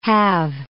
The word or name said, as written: halve